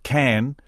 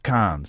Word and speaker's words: Cannes: By the way, thanks to the texter who wrote, it's pronounced can, not can. So you can't in can, but you can in can.